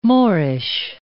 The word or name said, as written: Moorish